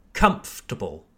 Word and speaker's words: comfortable: In French, there's an extra sound, okay? Confortable. But in English, we don't pronounce the or, okay? It's just comfortable, comfortable. Again, we place emphasis on the first syllable, comfortable, not comfortable. It's comfortable. Okay, one more time.